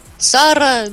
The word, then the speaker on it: Сарра: Скажи, пожалуйста, вот изначально правильно ударение ставится? Сарра или Сарра? – Сарра, ну как еврейское древнее имя.